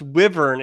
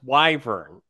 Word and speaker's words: wyvern: Also, Peter, it's not pronounced wyvern. It's pronounced wyvern.